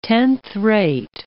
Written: tenth-rate

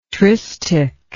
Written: tristich

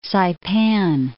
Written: Saipan

